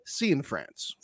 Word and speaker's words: Cianfrance: And lastly, Derek Cianfrance is in oh. fact Derek C in France. C in France. – Yeah, C in France.